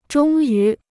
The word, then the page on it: 终于: zhōng yú